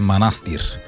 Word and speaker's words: манастир: Веднага Михаил Делирадев ме контрира с абе, знаеш ли, че ударението е манастир? – Ами това е като винарска изба Драгомир.